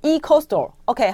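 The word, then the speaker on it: ecostore: 胶原美肌枕啊，还有这个呃科伊桑博士茶的内外保养组。我刚才讲的那个居家清洁组呢，是 Eco，这要怎么念啊？Eco Store 是这样子吗？Eco Store。 – Eco Store，OK，好，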